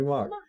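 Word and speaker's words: Mauch: Hey, that's the guy's name, Tony Mock. – So, is that how you say it? – No, it's M-A-U-C-H. – I mean, that might be Mock. Mock? – Mock.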